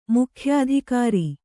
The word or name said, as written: ಮುಖ್ಯಾಧಿಕಾರಿ